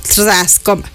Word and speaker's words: Trzaskoma: Su incorporación se produjo 2 días después de que el abogado principal de Maduro, Barry Pollack, anunciara su llegada al bufete Harris Trascoma. ¿Cómo se pronuncia? Está escrito T-R-Z-A-S-K-O-M-A, Trascoma.